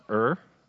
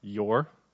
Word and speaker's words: Ur: And by the way, that's pronounced Ur, not your. Those of you from the texting generation, I found out that UR is your recently. Not too recently, actually. Few years ago, but that's Ur, not Yor of the Chaldees.